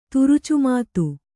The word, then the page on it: ತುರುಚುಮಾತು: kannada